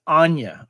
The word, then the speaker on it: Anya: Just to clarify, too, it's Anya. I have been mispronouncing her name for quite a while. It's Anya.